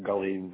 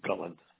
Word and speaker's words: Gullane: Gullen, G-U-L-L-A-N-E. – Z. – some pronounce it Gullion, but it's Gullin.